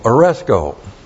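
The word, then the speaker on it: Oresco (ἀρέσκω): And in Thessalonians, we have the same verb that we have in this passage, which is the verb aresco. A-R-E-S-K. Long O, Oresco.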